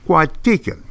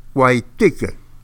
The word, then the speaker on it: Coahuiltecan: All you Spanish speakers, I will give you what I call it in East Texan. Cuatican. The Cuatican. Now, because no one has written to get a study guide, a lecture guide, I'm going to spell it for you, and you can see how I mispronounced it in Spanish. All right? Here we go. C-O-A-H-U-I-L-T-E-C-A-N. Cuatican. The Cuatican. C-O-A-H-U-I-L-T-E-C-A-N. Cuatican. So, Spanish speakers, I know you're giggling. I'm sorry, that's the way we pronounce it in East Texas.